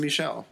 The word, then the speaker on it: Michel: No, he is from France and pronounced Michel.